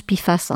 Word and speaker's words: PFAS: On prononce PFAS, hein.